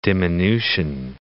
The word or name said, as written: diminution